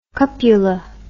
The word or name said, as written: cupula